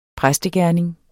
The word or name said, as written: præstegerning